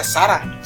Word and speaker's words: Zara: se pronuncia Zara. Sí, claro.